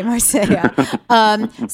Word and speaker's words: Marsella: Am I saying it right? I look at the way it's spelled, and I know it's pronounced differently, and it totally, like, gives me a panic attack. – it's Marsella. You almost had it. – Okay, Marsella.